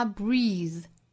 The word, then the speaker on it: breathe: 那后面这个 breathing 我们要注意啊，这是一个动词，发音是长音的 e，尾部的 th 是浊辅音的 v，所以这个词发 breathe，单词尾部加 ing 读成 breathing，前紧后松 ing breathing。